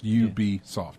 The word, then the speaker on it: Ubisoft: So you just take Ubiquitous and then Ubi, there you go. Exactly like you pronounced, Ubisoft.